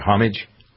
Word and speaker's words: homage: You said homage. Is that like homage? – Mm-hmm. – Homage is the right way to say it.